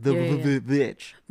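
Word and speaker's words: The VVitch: the way it pronounced it, it like the title is like 2V, so it's like The Witch.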